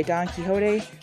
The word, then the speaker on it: Quichotte: and it's spelled Q-U-I-C-H-O-T-T-E, which is say Don Quixote, by Magazine 60, and number 56 was as high as this would get.